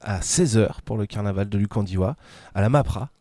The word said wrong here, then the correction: MARPA: À la MARPA. – MARPA, pardon, je vais mieux prononcer ça, et c'est où la MARPA ?